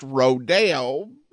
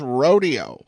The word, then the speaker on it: rodeo: I often hear it pronounced Rodeo, but, you know, around where I live, I've never heard it pronounced Rodeo. I've always heard it as Rodeo.